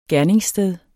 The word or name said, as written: GERNINGSSTED